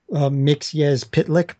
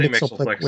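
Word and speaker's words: Mxyzptlk: Oh, before anybody like makes any comments, I know it's pronounced Mixyez Pitlick, but, you know, I learned Mixleplick way back all the time.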